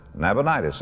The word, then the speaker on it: Nabonidus: It was a man named Nabonidus or Nabonidus, whichever way you prefer to pronounce it. Nabonidus is the way I'll pronounce it.